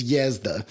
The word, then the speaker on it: Jezda: It is, the J is a Y, basically. So it's Jezda and Jezdimir Vasilievich.